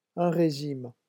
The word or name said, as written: un regime